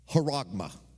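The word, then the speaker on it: haragma (χάραγμα): The word mark comes from the word haragma. Haragma. That's the Greek word. Haragma. C-H-A-R-A-G-M-A, but pronounced haragma.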